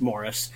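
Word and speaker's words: Maurice: For those who have not heard of Phantom of the Open, it stars Mark Rylance in the true story of Morris Flitcroft, I should say, because it's in England and they pronounce it Morris,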